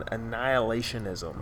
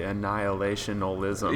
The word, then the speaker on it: annihilationism: And we thought it would be interesting and helpful to have a discussion on annihilationism. – Okay. – Yeah. Did I say that right? – You did. – Annihilationism. – Yeah, yeah, that was good. Sometimes I want to say annihilationalism.